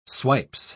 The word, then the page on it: swipes: British English